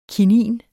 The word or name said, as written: kinin